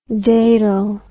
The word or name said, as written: datal